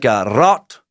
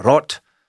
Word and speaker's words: Rod: Rod is how it's spelled. It's more like Rot is how I hear it said by people who speak the language on YouTube. Rot. I love that it's just in English, though, just spelled Rod, R-O-D.